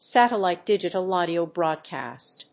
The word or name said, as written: satellite digital audio broadcast